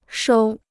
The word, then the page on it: 收: shōu